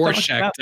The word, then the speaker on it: Rorschach: But if you did like a Rorschach test with, you know, the Rorschach test, yeah. – Rorschach, you know what I'm saying over there.